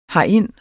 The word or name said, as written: herind